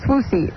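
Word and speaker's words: Swoosie: It's Swoozy. Rhymes with Lucy.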